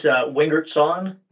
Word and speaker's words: Wingerston: It's Wingerston. – Wingerston. – Everybody likes to take the S and the T, and they do the little swap on it.